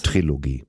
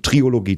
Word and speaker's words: Trilogie: Ich bin Klugscheißer schreibt man mit K und der Trilogie lässt man das O weg. Bei Klugscheißer in der Mitte schreibt man ein äh, K? Hm, wer soll es dir sonst erklären, wenn nicht ich? Okay. Ähm, Trilogie. Das ist eine schlechte Angewohnheit, Triologie zu sagen.